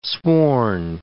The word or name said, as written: sworn